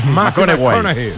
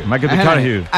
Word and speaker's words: McConaughey: McConaughey. McConaughey.